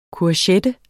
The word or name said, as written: courgette